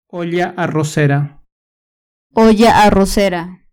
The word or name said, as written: olla arrocera